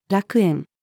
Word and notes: 楽園: female

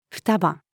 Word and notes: 双葉: female

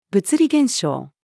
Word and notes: 物理現象: female

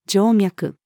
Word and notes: 静脈: female

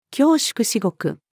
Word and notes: 恐縮至極: female

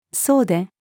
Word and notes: 総出: female

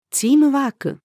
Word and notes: チームワーク: female

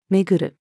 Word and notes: 巡る: female

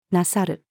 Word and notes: なさる: female

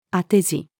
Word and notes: 当て字: female

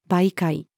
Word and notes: 媒介: female

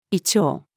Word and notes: 胃腸: female

